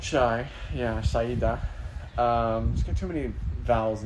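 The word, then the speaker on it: Shaeeda: Except they don't say that. She says Shaida. – Shaida. – Shaida. – Yeah, it's S-H-A-E-E-D-A. – I know how it's spelled, but she says Shaida. – I know. Yeah, there's one too many vowels.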